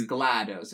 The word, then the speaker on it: GLaDOS: It's GLaDOS. It's a short A because it's supposed to sound like Gladys.